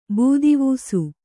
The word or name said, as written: ಬೂದಿವೂಸು